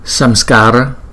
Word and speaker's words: Samskara: Có chữ SR này, chúng ta đọc là chữ SR bình thường, S. Hai chữ SR này nè, đọc bình thường, S. Samskara, Samskara, Samskara. Chúng ta kéo chữ A này hai nhịp, còn chữ A sau này là một nhịp, chúng ta đọc là Samskara.